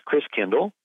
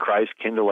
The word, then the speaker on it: Christkindl: And the German pronunciation of Christ child is Christkindl. Christ meaning Christ, Kindle like kinder care, kindergarten means child. So Christkindl ends up being pronounced Kris Kringle. So Kris Kringle is really Christkindl, which means Christ child.